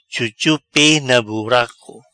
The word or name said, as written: Chuchu pe na buraco